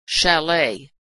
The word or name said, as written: chalet